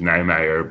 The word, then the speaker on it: Niemeyer: The Dutch pronunciation is Niemeyer, but I've heard it all before.